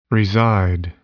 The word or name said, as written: reside